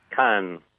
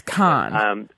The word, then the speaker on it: Cannes: Is it Cannes or Cannes? – Cannes. – Cannes. And is that how it's pronounced in French? – I believe so. – So it's con.